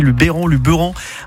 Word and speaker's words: Luberon: Je dis bien Luberon parce qu'on a appris à le prononcer aujourd'hui. Luberon, avec notamment la chronique de Saviez-vous.